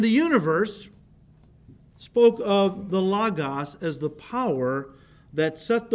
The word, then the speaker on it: logos: You see, in Greek, the word John uses for the word is logos. Logos, not logos. That's a mispronunciation. It's logos, okay?